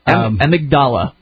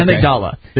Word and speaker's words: amygdala: Amy, I don't know how you pronounce that. Amygdala? Amygdala. That's what it is. – Okay. – Amygdala.